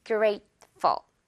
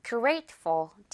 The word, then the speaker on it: grateful: I'm so grateful to you. I'm so grateful to you. Grateful 아니죠? Grateful. 가벼운 깃털. 오케이? 여러분, 기억할 수 있겠죠? F-U-L, 깃털 발음.